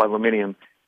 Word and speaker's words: aluminium: You know, they grew up together in the same area, and it was their aunt that owned, and they put another syllable in aluminum called aluminium, owned an aluminium processing plant, like a Reynolds aluminum, only a lot smaller.